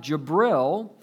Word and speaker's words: Jibril: And number five, Jibril. We would say Gabriel. J-I-B-R-I-L. We would say Gabriel, but this is the pronunciation of Gabriel.